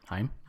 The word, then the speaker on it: Haim: Haim.